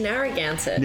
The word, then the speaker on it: Narragansett: I think it's Narragansett.